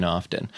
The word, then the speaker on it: often: And then it's and you don't have to pronounce the T in often. Yeah. People think they sound smart when they do it, but it's actually it's often.